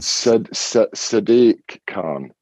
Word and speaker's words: Sadiq: Sadiq, how is that pronounced? Sadiq, where's the stress in? – Sadiq Khan.